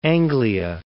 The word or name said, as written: Anglia